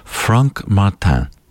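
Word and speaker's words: Frank Martin: The featured work this week on the third floor, the Mass for Double Choir by, and let's get this pronunciation right, as this composer was Swiss, Franck Martin.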